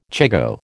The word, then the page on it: chigoe: American English